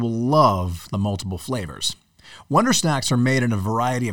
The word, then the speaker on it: Wondersnaxx: Make sure, once again, you type in the search bar, Wonder Snacks as it's spelled, that's Wonder, and then S-N-A-X-X.